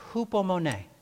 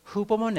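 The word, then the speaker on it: hupomone (ὑπομονή): The Greek word that could be translated either perseverance or endurance is pronounced hupomone. It's one word, hupomone,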